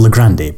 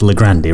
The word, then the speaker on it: Le Grande: supposed to be pronounced Le Grande rather than Le Grand, which I assume is what you'd expect it to be called,